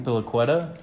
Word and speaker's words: Azpilicueta: Azpilicueta. Thank you. Azpilicueta. wow. Yeah. Okay. Uh, Azpilicueta.